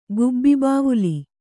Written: ಗುಬ್ಬಿಬಾವುಲಿ

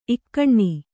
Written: ಈಗಿನ

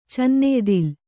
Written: ಚನ್ನೆಯ್ದಿಲ್

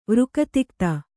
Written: ವೃಕತಿಕ್ತ

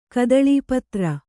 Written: ಕದಳೀಪತ್ರ

